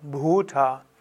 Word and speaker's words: Bhuta: Bhuta, das H ist hörbar, Bhuta. Also nicht Bhuta, sondern Bhuta.